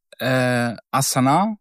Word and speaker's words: Astana: Jetzt ging es um Astana.